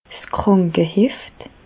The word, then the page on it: 's Krànkeheft: Bas Rhin; Reichshoffen